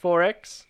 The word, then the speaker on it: XXXX: So it's X's, it's XXXX, pronounced 4X.